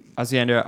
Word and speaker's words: Hacienda: It's Hacienda. Am I saying that right? Hacienda Agricole, like basically house making wine. – You actually said that really well.